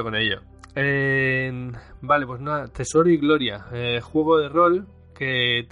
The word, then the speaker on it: Tesoro y Gloria: Del juego de rol eh, Tesoro y Gloria. Lo he dicho bien, ¿no? – Efectivamente. De hecho, creo que eres de los primeros que lo dice bien porque la gente suele decir tesoros y gloria.